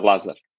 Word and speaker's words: Lazar: Am I saying it right? Lazar or Lazar? – It's Lazar.